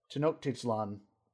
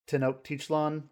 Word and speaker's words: Tenochtitlan: Yeah, it's a massive, probably one of the biggest tribes, and maybe what it is almost like a Tenochtitlan. I just butchered that pronunciation. Tenochtitlan, the ancient forerunner of Mexico City.